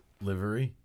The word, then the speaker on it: livery: It's L-I-V-E-R-Y. – It's livery. – Livery? – Craig is correct. – That cannot be right. – It's a livery. – It's livery, which is what?